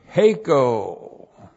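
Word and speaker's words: Heiko (ἥκω): And then we have the verb heiko. H, long E, K, long O. Heiko.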